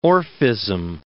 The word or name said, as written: Orphism